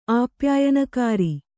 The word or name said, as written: ಆಪ್ಯಾಯನಕಾರಿ